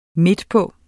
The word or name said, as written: midtpå